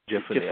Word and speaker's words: Jephunneh: Two of the men had explored the land, Joshua son of Nun and Caleb son of, how do you pronounce that, Oshel? Jephunneh? – I believe it's, Jephunneh. Jephunneh, I believe that's pronounced.